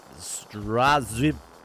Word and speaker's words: Sdraziw: You would pronounce that as Straziv. S-D-R-A-Z-I-W.